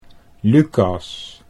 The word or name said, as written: Lucas